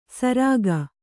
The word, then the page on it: ಸರಾಗ: Kannada